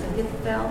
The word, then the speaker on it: Ahithophel: And then with David, one of his close friends, Ahithophel, I think you pronounce it, betrayed him. – That's right.